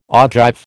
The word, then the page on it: ogive: American English